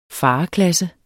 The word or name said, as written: fareklasse